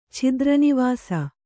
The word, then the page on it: ಛಿದ್ರನಿವಾಸ: chidra nivāsa